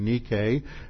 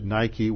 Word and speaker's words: Nike: Nikao is where is based on the noun Nike, for the goddess of victory, the Greek goddess of victory, Nike, and you mispronounce it Nike when you get your athletic shoes.